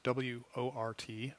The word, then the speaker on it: wort: Now, the liquid that is coming off that mash is known as wort, WORT, pronounced wort. You might see this written. It's pronounced like wort.